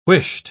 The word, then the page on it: whisht: British English